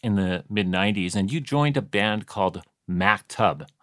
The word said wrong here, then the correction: Maktub: I'm saying it right, right? – It's Maktub. – Ooh. Yeah. Oh, it's Maktub. My apologies. Maktub. – No, it's all right. It's an Arabic word.